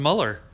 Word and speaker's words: Mueller: Mauler. – It's special counsel? – Right. – Mueller? – Robert Mueller. – It's pronounced Mueller.